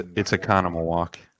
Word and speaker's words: Oconomowoc: It's Oconomowoc. But it like the way it's spelled it's pronounced Oconomowoc.